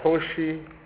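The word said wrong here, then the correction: Khosi: How is it pronounced? – Kosi Kutama.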